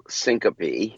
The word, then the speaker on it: syncope: Vasovagal syncope? What is this? – Vasovagal syncope.